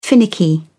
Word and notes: finicky: British English

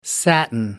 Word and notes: satin: American English

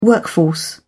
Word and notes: workforce: British English

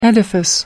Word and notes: edifice: American English

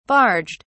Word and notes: barged: British English

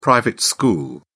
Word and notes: private school: English; British English

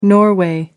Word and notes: norway: American English

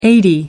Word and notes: eighty: American English

